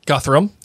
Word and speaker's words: Guthrum: G guy, Guthrum.